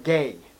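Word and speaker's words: gay (γῆ): C-O-N-T-I-G-U-I-T-Y. Contiguity? – Continuity. – Okay. Now, look at the word earth in verse 8. It's the word gay, G-E, long E, gay, G-E.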